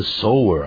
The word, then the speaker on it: sour: Sour 可变成 sour 啊，这念成 sour 啊，S-O-U-R 有酸的变坏了啊。